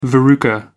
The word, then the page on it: verruca: American English